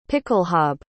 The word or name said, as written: pickelhaube